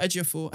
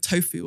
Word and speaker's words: Ejiofor: And she mispronounces John Boyega's name. She calls him like John Bodega. And Chiwetel Ejiofor. I think she calls him like Tofu or something.